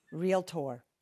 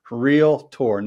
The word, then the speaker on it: REALTOR: Realtor. – Realtor, not Realtor. You don't say Doctor.